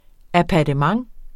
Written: APPARTEMENTER

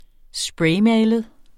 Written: spraymalet

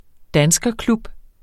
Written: danskerklub

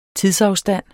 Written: tidsafstand

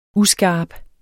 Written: uskarp

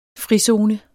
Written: frizone

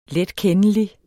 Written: letkendelig

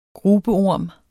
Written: grubeorm

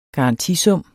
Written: garantisum

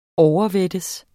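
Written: OVERVÆTTES

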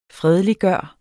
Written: fredeliggøre

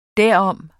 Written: derom

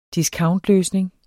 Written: discountløsning

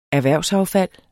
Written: erhvervsaffald

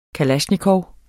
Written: kalasjnikov

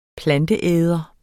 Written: planteæder

